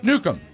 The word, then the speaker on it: Neukom: Chuck Newcomb. – What's it? Could you spell that for me, please? – I can. N-E-U-K-O-M. It's not like a like he's going to bomb anybody.